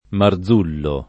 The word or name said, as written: Marzullo